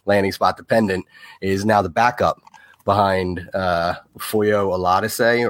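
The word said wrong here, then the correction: Foyesade Oluokun: Foyesade Olokun.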